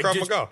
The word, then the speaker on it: Krav Maga: Krav Maga.